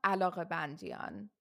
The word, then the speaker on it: Alaghebandian: Hiva Alagabandian.